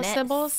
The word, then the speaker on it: Ceybeles: How do you spell Sybil's? – C-E-Y-B-E-L-E-S. – Nothing is spelled even close to how I would think it is. – I've landed on that's the pronunciation because I'm like, I would go with Sables.